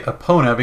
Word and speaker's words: Epona: I say Epona because, like, it's E-P-O-N-A, and to me that's like pony. So it's the pone sound, so Epona.